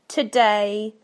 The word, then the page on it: today: British English